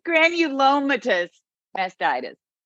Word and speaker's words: Granulomatous mastitis: Granulomatous mastitis.